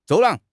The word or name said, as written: 走廊